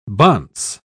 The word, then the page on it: bonce: British English